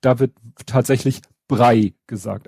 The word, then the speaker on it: Braille: ist, es wird nicht nur nicht Braille, es wird auch nicht Brei ausgesprochen, sondern Brei. Oh. – Also wieder Haferbrei. – Mhm. – Und das Witzige ist da doch wieder, noch, es wird immer witziger. Ich habe mir dann mal äh, eine englischsprachige Aus, Aussprache angehört und die war wirklich Braille.